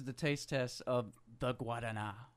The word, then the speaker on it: Guarana: Guadana. Me and her went back and forth voice messaging, and she that, yeah, she said that I'm pronouncing it right.